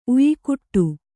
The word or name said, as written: ಉಯಿಕುಟ್ಟು